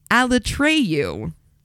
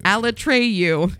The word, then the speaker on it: Alatreyu: Alatreyu. – Alatreyu. – Alatreyu.